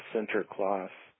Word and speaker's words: Sinterklaas: It was of a somewhat Dutch origin, and forgive the pronunciation. I believe it's pronounced Sinterklaas.